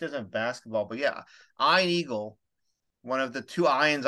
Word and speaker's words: Eagle: It's not Ian Eagle. It's Ian Eagle.